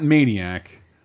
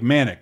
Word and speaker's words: Manic: That would be Sonia and it's not Maniac. Maniac? How do you say it? Like Manic.